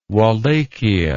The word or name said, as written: walachia